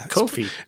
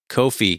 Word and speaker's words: Ko-fi: Ko-fi. Man, I can't remember how to say it. – Ko-fi. – Rhymes with no fee. That's their shtick. Ko-fi.